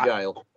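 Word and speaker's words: gaol: Or put Derby Jail in, and it can, but it's G-A-O-L, Derby Jail. – And Derby is spelled with how? – D-E-R-B-Y, mm-hmm.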